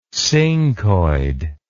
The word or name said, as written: scincoid